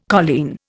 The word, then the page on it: colleen: Indian